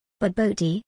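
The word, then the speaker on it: Bobotie: Baboti, pronounced Baboti, is a Cape Malay creation made with minced beef and topped with a savory egg custard.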